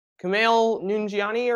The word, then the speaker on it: Kumail Nanjiani: Kumail Nanjiani, or is that how you pronounce it? – Yeah.